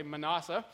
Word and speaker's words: Manasseh: Conversely, if we go down to verse 10, it says here, and Hezekiah, the father of Manasseh, and I was corrected on my pronunciation of Manasseh earlier. Ed didn't like the way I was pronouncing Manasseh, so I'll say Manasseh.